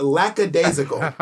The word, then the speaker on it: lackadaisical: No, you're saying lackadaisical. You're adding an S or an X that doesn't exist. It's lackadaisical.